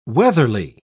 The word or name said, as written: weatherly